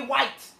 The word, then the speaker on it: White: But why do you keep calling him Wyatt? – Because that's how they say it. Jay White. Jay White. – His name is Jay White.